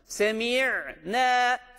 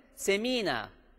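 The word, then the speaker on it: Semi'ne (سمعنا): Semi'ne, semi'ne. Bu doğru okuyuştur. Yanlışı nedir? Semina. Bakın bu ya harfi burada yoktur met harfi.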